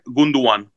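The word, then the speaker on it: Gündoğan: el segundo que es interesante, Ilkay Gundogan, ¿cómo se pronuncia, estoy 100% seguro. Ilkay Gunduan. Gunduan. Gunduan.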